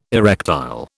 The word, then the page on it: erectile: British English